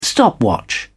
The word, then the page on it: stopwatch: British English